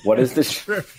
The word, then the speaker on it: Straya: They're saying Straya, which is Australian for Australia. Straya.